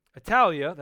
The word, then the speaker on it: Attalia: And when they had spoken the word in Pergia, they went down to Attalia. That's how they say it today.